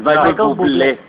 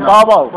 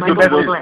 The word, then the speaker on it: Bublé: Yo sé hablar muy bien inglés y se pronuncia Bubble. – No, es Michael Bublé. Porque es francés y le ponen un acento al final.